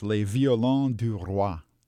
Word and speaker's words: Les Violons du Roi: The correct name of the group is? – In French, Les Violons du Roi.